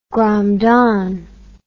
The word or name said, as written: gramdan